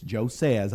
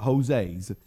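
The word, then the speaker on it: Joses: Mary Magdalene and the Mary of uh, mother the, and the mother of Joe Joe says it's Joe says I looked it up to pronounce it right not not Jose's Joe says were looking to see where he was laid.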